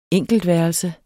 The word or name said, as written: enkeltværelse